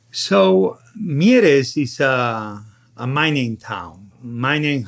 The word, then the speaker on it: Mieres: Is it Mieres? – Mieres.